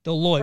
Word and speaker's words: Deloitte: Deloitte.